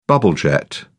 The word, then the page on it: bubblejet: British English